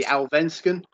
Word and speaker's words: Allsvenskan: You've got the Swedish league as well, which is the Allsvenskan, I believe it's pronounced. – Yeah, you're right.